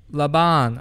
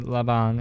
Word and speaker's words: Laban: Laban. I don't know how you say it in English, but my family calls it Laban, and it says L A B A N.